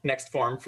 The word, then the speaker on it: NXT Farm: NXT Farm, or NextFarm, depending on how you want to pronounce it, came to be? – Yeah, for sure. So we'll go with NextFarm for today.